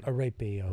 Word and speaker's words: Arpaio: I mean, you're mispronouncing. – Arapio. – Arapio. It's ethnic.